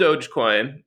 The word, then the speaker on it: Dogecoin: This doggy coin, it's actually called Dogecoin.